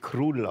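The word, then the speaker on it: Król: Es heißt ja König, polnisch. – Ja. – Da wird eigentlich Krul ausgesprochen. – Krul. – Wenn man das Krul. – Krul. – Ja. Wie Wutsch, Lodge. – Ah, okay. – O, Akzent, U.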